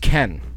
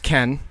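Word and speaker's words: Cannes: It's actually pronounced Ken, the film festival. So it got great reviews at Ken, but a lot of critics... – Ken? – Were like Ken Boyle? – Yes, like Ken Boyle.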